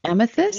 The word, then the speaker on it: Amethyst: And so he put his hand out, and I said, oh, my God, you have the Amnes birthstone, whatever. I'm not pronouncing it right because I'm talking too fast. – Amethyst? – He was like, yeah, Amnes.